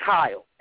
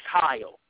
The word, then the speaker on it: Hyle: It's Hyle, H-Y-L. Call me H. – Huh? – It's Hyle, H-Y-L. That's how you spell it, Kyle. Like Kyle, but with the H, no E.